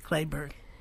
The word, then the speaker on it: Kelberg: Of course, Clayburgh's name is spelled K-E-L-B-E-R-G. He's so far under the radar, at least for me. I've been here over 35 years that I had to call someone to get the correct pronunciation. – You have it right. You have it exactly right. Clayburgh.